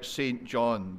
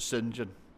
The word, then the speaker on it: St. John: It would look like St. John, but believe me, you pronounce it St. John.